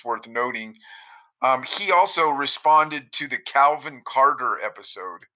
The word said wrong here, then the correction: Cottar: Oh, Calvin Cotter. Oh, family, yeah. The Cotters. – Spell that, because your accent. – Cottar, Cotter. – Oh, I've been saying it wrong.